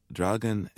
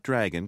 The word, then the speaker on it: Draugen: You call it the Draugen and the Keg. – Hmm. – How do I say it? Not dragon, because the English word is dragon, but this word is? – Draugen.